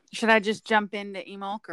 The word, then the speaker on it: Imbolc: So that means while you up there in the Northern Hemisphere are celebrating Lunasa, down here in the South, we will be celebrating Emulk. So it's spelled I-M-B-O-L-C, but the old spelling is O-I-M-E-L-C, which is Gaelic, and it literally translates to mean ewe's milk.